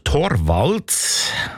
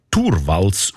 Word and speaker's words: Torvalds: Der Linus Torvalds, eigentlich Turvalds ausgesprochen, damals Informatikstudent Helsinki, er hat die wertvollste Software der Welt namens Linux einfach verschenkt.